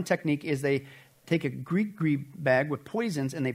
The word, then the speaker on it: gris-gris: Now, sometimes they'll use, like you saw, like a bag, again, a gris-gris is actually how it's pronounced, gris-gris.